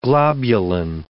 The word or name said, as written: globulin